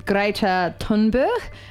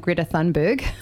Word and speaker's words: Thunberg: So it was kind of all started by a girl called Greta Thunberg. Is how I believe you say her name, but in Kiwi we've been saying Greta Thunberg.